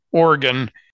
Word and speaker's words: Oregon: Excuse me, Oregon. I got somebody from Oregon criticized my pronunciation of, and so it's Oregon.